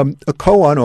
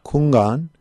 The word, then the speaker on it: koan: A koan or kungan, the Chinese way to pronounce it, is literally it means a public case.